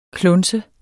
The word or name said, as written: klunser